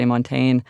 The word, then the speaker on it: Montaigne: Montaigne. That is much easier to do than I was trying to give it like this French flourish, but apparently you can just say Montaigne.